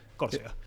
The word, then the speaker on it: Córcega: Uno es Córcega, o Corsica, no sé cómo se dice. – Sí, Córcega.